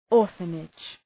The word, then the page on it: orphanage: ‘ɔ:rfənıdʒ